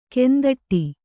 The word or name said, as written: ಕಂದೊಡಿ